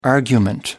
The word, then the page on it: argument: American English